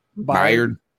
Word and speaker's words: Byard: Byard.